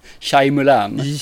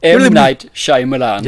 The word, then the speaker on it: Shyamalan: Shyamalan. M. Night Shyamalan. Det är er Mulan du ska komma ihåg bara.